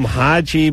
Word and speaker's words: Haji: So we call him Haji, but we put the emphasis on the G.